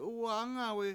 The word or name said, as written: 7uwa7Angawi7